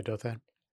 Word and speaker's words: Douthat: Yeah, Douthat.